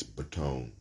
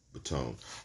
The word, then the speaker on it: Bottone: I think it's Batone. B-O-T-T-O-N-E. Batone.